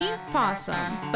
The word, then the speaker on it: opossum: opossum. – But it's spelled opossum. – The O is silent.